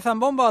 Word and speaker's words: zambomba: Antes de entrar en materia, ¿se dice zambomba o zambomba? Que estamos aquí, Mónica y yo, con la duda. – Zambomba. – Zambomba. No con acento en la A final, sino normal.